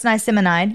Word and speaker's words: Niacinamide: Niacinamide? – Yeah, pretty close. Niacinamide.